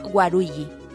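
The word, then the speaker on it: Waluigi: También es el mismo caso para Waruigi, Luigi Malo, y funciona mejor en Japón, donde su nombre se pronuncia Waruigi.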